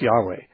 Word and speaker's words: Yahweh: Modern scholars would say it's better pronounced Yahweh.